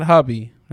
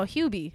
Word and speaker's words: Hubie: Hubie? It's not hubby, right? – No, Hubie. His name's Hubie.